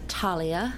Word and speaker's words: Talia: Well, it sounds like Talia and it's spelled T-A-L-I-A.